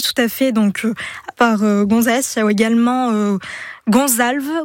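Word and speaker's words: González: Alors, attention, Gonzalez avec un accent sur le A, donc bien marqué, oui. Espagnol. – Le fameux accent tonique.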